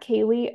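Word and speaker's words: Kaely: it's at Kaylee RD, and my name is spelled a little weird.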